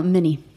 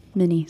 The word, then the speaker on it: Mini: No, Mini. Mini. Så det blir, de tror att det är som Mini Mouse.